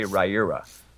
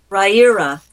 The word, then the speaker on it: Rieira: Rye, as in the bread, ear, as in the body part, and ah, as in ah. That's how you say Rieira. – Rieira.